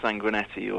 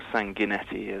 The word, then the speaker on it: Sanguinetti: He's Spike Sanguinetti, or Sanguinetti, as the local Gibraltarians would pronounce it.